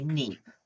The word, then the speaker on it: Kneipp: We would say Kneipp. I think it's spelled K-N-E-I-P-P, Kneipp or Kneipp.